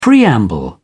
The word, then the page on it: preamble: British English